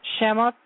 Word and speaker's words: Shemot: Shemot, names, Exodus.